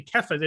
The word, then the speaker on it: Kepha: Actually, everyone says Cephas, it's actually Kepha. It's a hard C, which is because it's coming from Kepha. It's coming from the Aramaic.